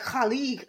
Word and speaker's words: Khaled: And we was telling her about DJ Khaled, and she was telling us how you pronounce Khaled properly. And it was like all in the throat. She was like, Khalid.